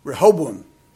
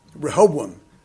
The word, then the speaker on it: Rehoboam: You're pronouncing it just like I've pronounced it all my life. Rehoboam. Rehoboam. I've asked him three or four times, what are you saying? And he finally pointed to it. And I went, oh, Rehoboam. And he just had this look of utter disgust.